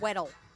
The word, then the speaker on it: Weddell: I like Weddell. – Well, it's because you're an Anglophile. – Yeah. – That is the English pronunciation, yes. – Exactly. – Like the Weddell Sea. – Weddell. – In Antarctica, so yes.